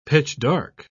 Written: pitch-dark

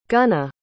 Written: gunna

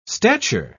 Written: stature

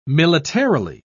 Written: militarily

